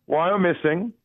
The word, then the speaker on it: Wyomissing: I'm glad you asked. It's Wyomissing.